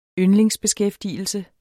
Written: yndlingsbeskæftigelse

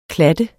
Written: klat

